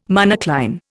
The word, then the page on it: monocline: Indian